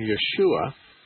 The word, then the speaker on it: Yeshua: And that Hebrew pronunciation, Yeshua, is transliterated in the Greek New Testament as Jesus. – So Jesus. – So it's Yeshua is the Hebrew and Jesus is the Greek.